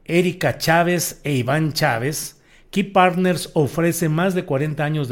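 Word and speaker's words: Kei Partners: Key Partners, que aunque le digo se escribe K-E-I, su logotipo es justamente el de una llave, Key Partners.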